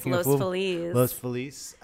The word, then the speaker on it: Los Feliz: We are in sunny Los Feliz. Am I saying that right? – Yes, Los Feliz.